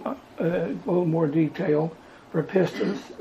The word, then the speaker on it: pistis: The Greek word used, the way it's pronounced according to the Thayer and Strong's, is, I guess, pistis. I guess it's yeah, pistis.